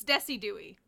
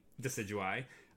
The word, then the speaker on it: Decidueye: Mispronouncing it as Desidooie.